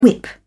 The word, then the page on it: whip: British English